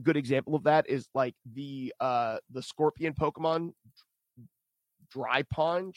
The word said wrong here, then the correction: Drapion: How do you pronounce that? – Drapion.